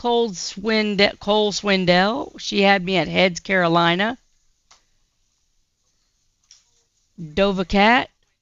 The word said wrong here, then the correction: Doja Cat: Doja Cat, Dova Cat, Doja Cat, Paint the Town Red.